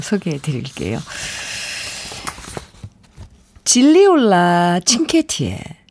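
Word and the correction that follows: Gigliola Cinquetti: 논호레타. 이 발음하기가 어려워서 그런 제가 좀 헤매는데요. 질리올라 칭케티의 논호레타 듣고 오겠습니다. 아, 네. 질리올라 칭케티의 노노레타.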